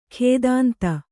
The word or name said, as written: ಖೇದಾಂತ